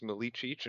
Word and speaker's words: Milicic: Last name is pronounced Milicic, and if anybody gets that right on the first try, I will give them a piece of candy.